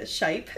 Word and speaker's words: Shyp: So, SHIP, S-H-Y-P... my partner Eric pronounces it Shipe, just to, you know, disambiguate.